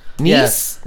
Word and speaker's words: Nisse: We have, I'm going to say Nisse? – Nisse. – N-I-S-S-E.